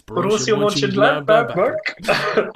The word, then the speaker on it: Borussia Mönchengladbach: It's Borussia Mönchengladbach.